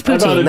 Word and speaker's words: poutine: Yeah, it is. It's poutine. That's the... That's the iconic Canadian dish that is pronounced horribly.